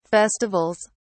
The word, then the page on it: festivals: British English